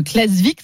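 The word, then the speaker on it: Klaksvík: C'est un événement euh, pour, pour le club de Klasvik, c'est comme ça qu'on prononce. Hein, Exactement, c'est bon.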